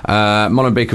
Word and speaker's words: Molenbeek: Am I saying that right? No? – Molenbeek. – Molenbeek